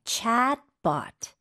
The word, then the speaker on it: chatbot: 네, 영어도 똑같지만 발음은 주의하셔야 돼요. 챗가 아니고 A가 좀더긴 느낌이고 입을 더 벌려야 돼요. Chatbot. – Chatbot. – Chatbot. – 네, 봇이 아니네요. 이것도 맞아요. – 로봇처럼 BOT는 bot으로 발음을 하셔야 됩니다.